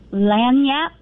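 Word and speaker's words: lagniappe: Just wanted to let you know it's pronounced Lanyap.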